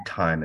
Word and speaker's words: Thieme: His name is Richard Thyme, and it's spelled T-H-I-E-M-E.